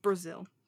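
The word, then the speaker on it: Brazile: Brazil. Like the country. Brazil. – Okay. – Trevor Brazil. – There's an L-E at the end, but okay. – Yeah, it's pronounced Brazil.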